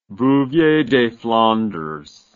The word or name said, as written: bouvier des flandres